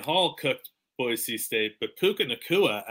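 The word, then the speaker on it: Puka Nacua: And then Puka Nuku. Nakua. Can you help me pronounce Nakua. This? – Puka Nakua. – Puka Nakua, another fun pronunciation coming to the NFL sometime in the near future.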